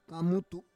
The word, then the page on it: kamutu: Roon